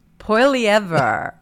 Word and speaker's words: Poilievre: In fact, let me use my little trusty little, let's see how it pronounces it. – Okay. – Pierre Poilievre. – Poilievre. There.